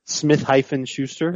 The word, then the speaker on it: Smith-Schuster: Smith-Schuster? – Yeah, thank you. That's exactly how to say it.